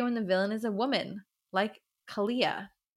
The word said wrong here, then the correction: Kayla: Kayla. – I know I said that wrong.